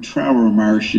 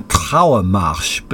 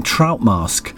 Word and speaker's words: Trauermarsch: And throughout it, he was referring to ein Trauermarsch, but his German pronunciation was rather dodgy. And Esther came home and said, I kept thinking he was saying trout mask.